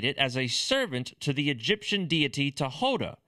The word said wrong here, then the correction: Thoth: Thoth, for those that don't know how the pronunciation is supposed to actually be.